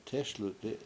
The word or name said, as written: tESluti